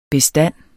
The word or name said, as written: bestand